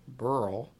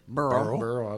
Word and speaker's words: Beryl: In 1948, a young Welshman named Timothy Evans and his wife, Burl, moved. – Burl? – B-E-R-Y-L. How would you say that? – Burl, okay. – You said it right.